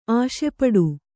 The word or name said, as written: ಆಸೆಪಡು